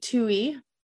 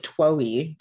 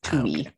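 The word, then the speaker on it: David Twohy: I thought it was Twoe, just by the way that it was spelled, but it's Tui.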